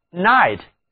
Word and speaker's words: knight: 最后我们来看一下 night, K-N-I-G-H-T, 骑士这个词，它比夜晚 night 只多了开头的一个字母 K, 两个单词读音是一模一样的。